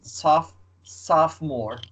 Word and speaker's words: sophomore: Pay attention to pronunciation. – Soph sophomore. Sophomore.